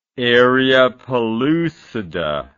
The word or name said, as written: area pellucida